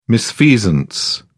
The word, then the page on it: misfeasance: British English